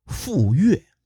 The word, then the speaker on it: 傅说: 几乎啊是同一个时期，武丁还遇到另外一个人，这个人叫傅说。这个“ 说” 是个多音字啊，其实啊就是说话的“ 说” 字。武丁遇见傅说的时候，傅说还是个奴隶。前面咱们说伊尹的时候提过，奴隶基本上是没什么姓名的。这个“ 傅” 字也是源于地名。傅说呢，曾经在傅岩这个地方当奴隶，也在今天的山西平陆县附近。